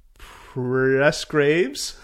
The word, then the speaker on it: Presgraves: This is Amanda Presgraves.